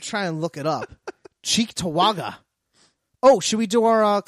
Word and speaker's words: Cheektowaga: No, it's a town called Cheektowaga. – Cheektowaga, New York.